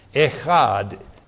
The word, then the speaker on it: echad: That particular word is called echad, and it's spelled, transliterated into English, E-C-H-A-D, echad.